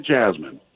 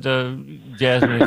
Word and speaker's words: jessamine: Carolina jessamine. – You got it. Jessamine is the way you pronounce it, but jasmine if you want to.